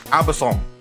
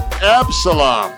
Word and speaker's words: Absalom: David is fleeing from a massive army sent by his own son, Absalom. I messed that up. – Hit that button. Hit that button. How do you not say Absalom? – Absalom? Is how you say that? – Yeah.